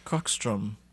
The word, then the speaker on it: Krokstrom: I think it's pronounced Krokstrom, KROKSTROM, Club and Market, but Club is spelled KLUBB.